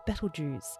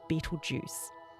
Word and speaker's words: Betelgeuse: That red star I mentioned just then is called Betelgeuse, often mispronounced as Betelgeuse.